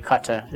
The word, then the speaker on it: Qatar: and actually Qatar is the right way to pronounce it, we have made this petrodollar system actually both oil and blood money.